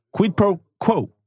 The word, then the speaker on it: Quid Pro Quo: Quid Pro Pro. Quid Pro Quo. Inte helt enkelt att uttala, men Nej.